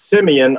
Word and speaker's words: Simeon: It's Simeon.